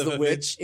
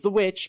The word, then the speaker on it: VVitch: It's The Witch. It's spelled V-V-I-T-C-H. So it's The Witch, but with two V's.